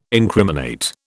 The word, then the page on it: incriminate: British English